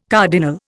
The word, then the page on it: cardinal: Indian